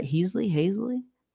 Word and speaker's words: Haseley: Haseley? – Yeah.